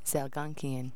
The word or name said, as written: zalgonkian